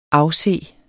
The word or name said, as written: afse